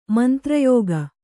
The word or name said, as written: ಮಂತ್ರಯೋಗ